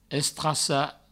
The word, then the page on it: estracar: Provençal; estrassa